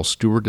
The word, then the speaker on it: Stuart: But if you will just Google me, my name is Carl Stuart, and that's spelled S-T-U-A-R-T.